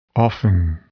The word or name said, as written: often